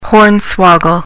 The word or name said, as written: hornswoggle